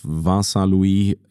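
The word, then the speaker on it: Vincent Luis: Abstand nach vorne auf Vincent-Louis. – Hast aber schön ausgesprochen. – Dafür, das war das erste Mal, dass ich das so ausgesprochen habe.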